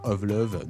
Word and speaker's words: Ovlov: Of Love, donc ça s'écrit O-V-L-O-V, sur Tentaculation tout de suite. Bien noisy, bien cradingue, cette fin du titre Land of Stevo, donc par Of Love, qui nous gratifie depuis quelques semaines maintenant de ce disque qui s'appelle Buds et qui vient tout juste de sortir, chers amis.